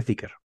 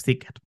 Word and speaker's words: Zeekr: Yo, yo lo iba a pronunciar Zicar, pero si tú me dices que es Zicker, pues... Creo que se dice Zicker. Pues Zicker.